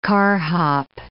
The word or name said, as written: carhop